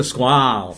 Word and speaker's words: Skwal: It's called a Squall. – Squall. – Squall. – So it's a Shark Squall helmet, which is spelled S-K-W-A-L.